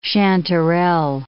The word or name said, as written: chanterelle